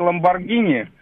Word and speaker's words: Ламборгини: Ну, во-первых, правильно говорить «Ламборгини», а во-вторых, хотел...